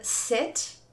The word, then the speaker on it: sit: Como exemplos, a palavra sit, S-I-T, sentar, e seat, S-E-A-T, assento. Né? Então, sit e seat têm sons diferentes, é um minimal pair.